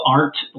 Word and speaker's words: Arndt: If you reach out to me on LinkedIn, it's Rob Arndt. Last name is spelled A-R-N-D-T, Alpha, Romeo, November, Delta, Tango.